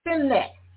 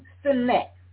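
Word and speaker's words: Sinach: Her name is Sinet. It's a Nigerian name, Sinet. And it's spelled S-I-N-A-C-H. S-I-N-A-C-H. Sinet.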